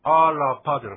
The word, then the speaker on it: アラパ: ですからアラパじゃなくてアラパというような感じで、 R ですから飲み込む感じですね。あ、ラとしっかり発音しない感じですね。